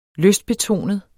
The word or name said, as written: LYSTBETONEDE